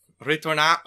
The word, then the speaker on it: Ritornato: Ritornato. Say it again. How do you say it? – Ritornato. Oh, yeah. Ritornato. – You gotta do this. Ritornato. – Ritornato. Perfect.